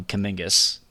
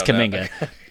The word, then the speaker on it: Kuminga: My whole point with the Warriors, and after watching them play last night, is if Kuminga, am I saying that right? Because Mikey's making fun of me that I keep calling him Kumingus. – I think it's Kuminga.